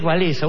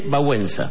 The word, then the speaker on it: Wałęsa: El otro era Lech Walesa, uno decía Lech Walesa y nadie le entendía porque se pronuncia Lech Wałęsa.